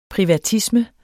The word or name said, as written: privatisme